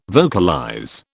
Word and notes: vocalize: British English

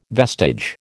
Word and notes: vestige: American English

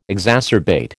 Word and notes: exacerbate: American English

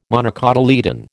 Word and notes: monocotyledon: American English

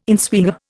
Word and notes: inswinger: Indian

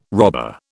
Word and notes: robber: British English

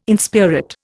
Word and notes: inspirit: Indian

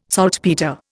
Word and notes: saltpetre: Indian

